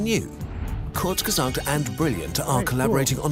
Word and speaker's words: Kurzgesagt: Kurzgesagt. That's how you say it, I think. – Kurzgesagt.